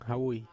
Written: havui

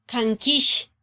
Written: kankyix

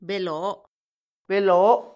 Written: beloo

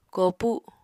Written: koopu

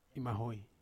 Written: mahoi

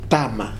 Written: tama

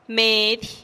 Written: medih

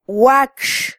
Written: wakx

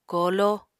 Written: kooló

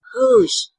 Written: guëzh

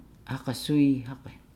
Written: hagasuihage

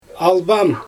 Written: albaan

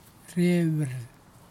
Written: rehwiir

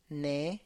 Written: ne

